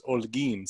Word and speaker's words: Holguín: But it's pronounced Olguin, so the H is silent.